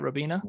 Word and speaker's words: Robina: Did I say that right, Robina? – That is correct, yes.